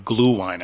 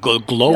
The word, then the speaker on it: Glühwein: So Glühwein, when you immediately said Glühwein, I started thinking Elmer's glue, but that's not glow wine.